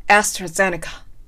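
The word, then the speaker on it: AstraZeneca: はい、ということで、アストラゼネカの発音は、アストラゼネカという風になります。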